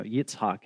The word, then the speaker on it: Yitzhak: You know, Yitzhak is actually how you say it, and his name means laughter.